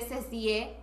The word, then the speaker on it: Cecie: pero todos me dicen Ceci, se escribe Cecie, pero la E no se pronuncia, es Ceci, porque mucha gente dice, hola Ceci, y yo, bro, bro.